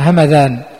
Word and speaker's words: همذان: المعجمة التي في العراق، بلدة في العراق همذان بفتح الميم والذال المعجمة، لكن هذه القبيلة اليمنية المشهورة فهي بإسكان الميم مع الدال المهملة الهمداني.